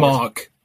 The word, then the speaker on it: Mauk: Yeah, Mauk.